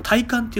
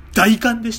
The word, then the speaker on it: 大寒: 大寒いと書いてあの体感って読んでたんだけどあれ大寒でした